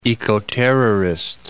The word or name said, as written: ecoterrorist